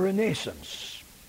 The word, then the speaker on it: Renaissance: I want you to notice how I pronounced that for your benefit. We were taught to pronounce it properly, the Renaissance.